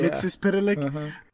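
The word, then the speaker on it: Mxyzptlk: I just got to the episode where she encounters, see if I get this guy's name right, Mixis Pitalik. – Yeah. – That's how you say it, yeah. Mixis Pitalik.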